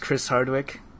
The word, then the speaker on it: Chris Hardwick: Is your name pronounced Chris Hardwick? – Yes.